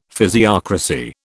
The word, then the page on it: physiocracy: British English